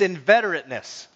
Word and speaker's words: inveterateness: It's inveterateness. I did say it right. Inveterateness. First one is inveterateness.